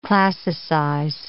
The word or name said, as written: classicize